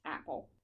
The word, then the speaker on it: Apol: He shook hands and told her his name was Apple, spelled A-P-O-L, but pronounced Apple.